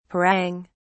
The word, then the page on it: parang: British English